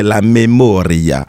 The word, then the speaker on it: La Memoria: On vient d'écouter le morceau de Jessy Reyes que j'ai écorché, dont j'ai écorché le titre, c'est La Memoria en espagnol.